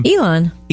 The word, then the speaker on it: Elon: Elon.